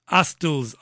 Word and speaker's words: Astles: Astles, I think it's pronounced A-S-T-L-E-S, Neil Astles.